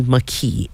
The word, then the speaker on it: Makee: It's spelled M-A-K-E-E, and so sometimes people have mistaken it as a Hawaiian word or a Hawaiian name and pronounce it Make'e, but it's actually Maki, M-A-K-E-E.